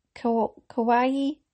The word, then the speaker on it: kawaii: And then there was some that were kawaii. And I says, how do you say that? And Daisy says, it's like Hawaii.